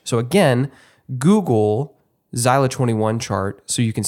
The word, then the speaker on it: Xyla: By the way, for those of you who do want to Google this, a Zeiler 21 chart is spelled XYLA.